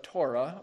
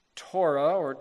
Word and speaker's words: Torah: Our English word law is translating a Hebrew word, the Hebrew word you've heard of, Torah, or Torah, actually, but Torah is the way that people normally pronounce it, which at its basic meaning is teaching.